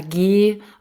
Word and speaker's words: ghee: Und diese Medikamente, diese Kräutermischung waren entweder mit Honig gemischt oder mit G oder G ausgesprochen, G-H-E-E geschrieben.